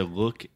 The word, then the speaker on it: look: okay, so look is the other U sound, which is the uh, uh sound.